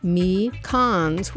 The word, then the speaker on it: Mekons: And then, this is probably like the 12th time I've mentioned it this morning, but hey, Mekons, pronounced Me Cons, will be at Bell's Eccentric Cafe in Kalamazoo tonight.